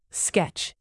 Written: sketch

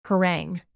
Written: harangue

